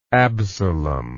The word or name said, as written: Absalom